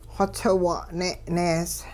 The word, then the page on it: xotsiwung'ne' nees: hupa